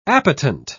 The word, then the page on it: appetent: British English